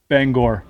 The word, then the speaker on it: Bangor: Yeah, hard OR. Hard OR, second syllable. Bangor.